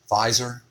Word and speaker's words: Pfizer: Cost of Viagra, and this is from, how do you say that company's name? – Pfizer? – Yes.